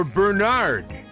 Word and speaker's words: Bernard: The first syllable rhymes with fur or sir, burr. Second syllable rhymes with bird or word, nerd. So Bernard. The emphasis stress in the place in the second syllable, Bernard, is confusing all of us, isn't it? – Today's saint is Saint Bernard of Clairvaux.